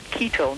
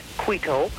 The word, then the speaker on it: Quito: Also, Quito. The city in Ecuador is pronounced Quito, not Quito. Whenever you have Q-U-I or Q-U-E, you drop the U sound so that Q-U-I-T-O becomes Quito, Ecuador.